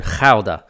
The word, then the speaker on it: Gouda: The name of the town I mentioned there, Gouda, you're probably thinking, what the fuck's that? Well, if I pronounced it Gouda, which is, of course, the English language pronunciation of it, then you go, you're close to Gouda.